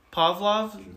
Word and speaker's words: Pavlov: Is that how you say his name? – Pavlov. Yeah.